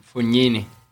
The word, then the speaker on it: Fognini: Fognini.